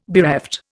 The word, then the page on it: bereft: Indian